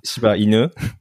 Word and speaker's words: Shiba Inu: Is it now, because you're, you're, you're, you're at the kind of epicenter of, uh, of this frenzy over this, uh, how do I pronounce this dog? It's a Japanese uh, Shiba. Shiba Inu.